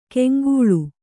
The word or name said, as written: ಕಂಕಳ